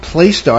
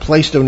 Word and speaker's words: Plaistow: I believe it's pronounced Plaistow. I've always pronounced it Plaistow, New Hampshire Public Library.